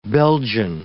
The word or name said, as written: Belgian